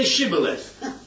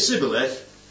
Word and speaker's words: Shibboleth: Says, if he replied no, they said, "All right, say Shibboleth." And if he said Sibboleth, because he could not pronounce the word correctly, they seized him and killed him at the fords of the Jordan.